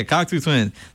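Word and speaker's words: Cocteau Twins: Yeah, Cocktoo Twins.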